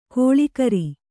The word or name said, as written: ಗಳೆಕಾರ